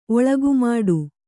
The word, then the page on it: ಒಳಗುಮಾಡು: Kannada